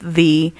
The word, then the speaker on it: ye: The Y, they started putting Y before it, and it became ye, but it was always pronounced thee. So when you see a sign that says ye old shop, you should say the old shop, because the Y should be a th sound, not a ye sound.